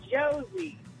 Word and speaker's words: Josie: Hello, my name is Josie, J-O-S-I-E.